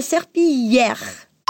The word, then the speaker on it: serpillière: Ah, e atenção para esse yeah, que você pode ver na transcrição, que escreve com dois L's, mas a gente pronuncia como se fosse um I comprido. Vai lá, passer la serpillière.